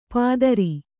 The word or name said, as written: ಪಾದರಿ